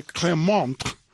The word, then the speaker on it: Clermont: Valois, perdón, ¿cómo se dice? Clermont. Clermont, ¿verdad? Así es la cosa.